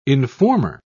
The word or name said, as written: informer